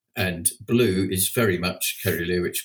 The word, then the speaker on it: caerulea: Well, almost certainly from the description, it's Passiflora caerulea, which uh, is a tricky word because there's many different pronunciations of it. You can also pronounce it uh, cerulea, cerulea, and uh, all the pronunciations are correct.